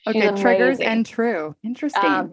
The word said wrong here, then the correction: Triggered and True: No, it's Triggered and True. – Oh, Trigger and True. Okay. – Triggered. – Triggered. Okay.